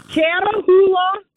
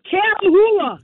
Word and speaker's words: Catahoula: It's called Catahoula. – Cattle. – Leopard dog. – Cattleo. – No. – Leah. No. Cattleo. – No. Catahoula.